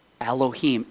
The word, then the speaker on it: Elohim: And the interesting thing about that name, Elohim, the Hebrew name, that's how it's pronounced, transliterated, Elohim, is that it's the plural form of a name that means God, the Supreme One, God.